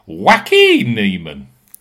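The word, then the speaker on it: Joaquín Niemann: Steve, though, you need to sort out your Neiman pronunciation. It is Wacky Neiman.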